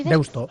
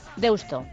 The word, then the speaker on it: Deusto: Sí, bueno, pero vamos, que a tope con los carnavales de Deusto, que por cierto, tenemos siempre discusiones con, ¿es Deusto? ¿Deusto? ¿Cómo se dice? – Yo Deusto he dicho toda la vida, ¿no? – ¿Cómo dices? – Deusto. – Deusto, vale.